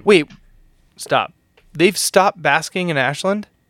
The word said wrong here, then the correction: busking: Busking. – Busking. Have I been fucking saying this wrong my entire life? Is it buskers, not baskers? – Yes.